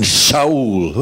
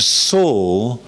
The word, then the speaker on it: Saul: People say, and Shaul. Well, that may be true technically, but it doesn't help people. And Saul.